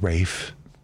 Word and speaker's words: Ralph: So it's Rafe. – Rafe. R-A-L-P-H? – It's Rafe. – I didn't know. – I know. It's strange, right? – He pronounces it Rafe? – It's Ralph Fiennes.